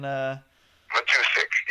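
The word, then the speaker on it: Matuszak: Matusik, yeah. Using my skills of Polish language, Matusik.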